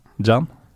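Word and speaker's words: Can: Det er slapt spill av Moreno på bekken og en Emre Jan som ikke klarer å klarere, som gjør at Bolassi kan banke den inn med venstrebeinet. Jeg tviler ikke på tyrkisk uttalelsen dine, men Emre Jan Jan. Jan? Mm. Ah, ja. Det er egentlig som i eh, det engelske John.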